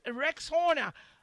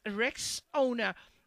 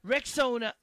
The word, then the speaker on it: Rexona: A friend even called it Rex Horner. But guys, it's Rexona. Like Rex and Ona, Rexona.